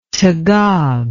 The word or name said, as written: tagab